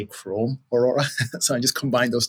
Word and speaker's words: Aurora: So you guys are looking at sites, their performance, the team you work on, Google's Aurora team. Am I saying that right? – Yeah, no, you're saying it perfectly right. Yeah, our specific team is Aurora, and we're part of Chrome.